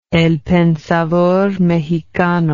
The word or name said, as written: El Pensador Mexicano